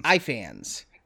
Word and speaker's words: Ifans: Then is it Ifans or Ifans? – Ifans. Reese, okay.